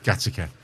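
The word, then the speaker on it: Gateacre: Like Gatacre. – Gatacre, yeah. Pronounced Gattaca.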